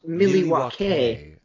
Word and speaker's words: Milwaukee: It's pronounced Milwaukee.